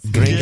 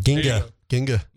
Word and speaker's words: gringa: No, it's but say it right, because if you gringa. Say it wrong, it's something else. – Gringa. – Yeah. You gotta gringa. – Gringa. – Ginga. – Gringa. It's not ginga.